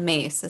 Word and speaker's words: Myss: What immediately came to mind is the book Anatomy of the Spirit by Carolyn Miss, M-Y-S-S. Actually, it's Carolyn Mace is how it's pronounced, but her last name is spelled M-Y-S-S.